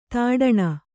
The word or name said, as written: ದಡಾಣಿ